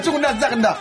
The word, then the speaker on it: Zoug Nazarna: Mistania, à ses côtés, Zoug Nazag et moi. – Zoug Nazarna. – Pardon ? – Zoug Nazarna. – Oui, c'est ce que j'ai dit. – Ah non, non, t'as pas prononcé comme c'est, ça. C'est... Zoug Nazarna.